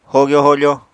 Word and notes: hogyo-hofo: apatani

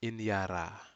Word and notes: inyara: Aneityum

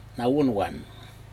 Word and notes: nawunwan: Aneityum

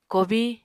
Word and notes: kobiñ: Apatani

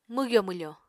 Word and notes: mwgyo-mwfo: Apatani

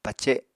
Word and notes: pace7: Apatani

